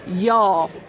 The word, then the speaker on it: y'all: You don't pronounce the apostrophe. Y'all. It is a term for a group. Y'all.